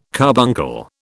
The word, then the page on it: carbuncle: British English